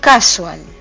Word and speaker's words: casual: Casual. Con acento en la primera A, tal como lo pronuncian los ingleses.